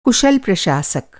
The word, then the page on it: कुशल: کشل